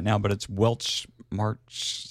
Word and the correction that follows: Weltschmerz: something like that. I could never even pronounce the words anyway, so anyway, there it is, Garden of Remembrance from Fish. It's sitting here right in front of me. It's Weltschmerz. There we go.